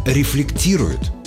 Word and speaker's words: рефлектирует: При этом человек, который предается рефлексии, рефлектирует, а не что-нибудь там еще. С ударением на «и» и «да» через «т».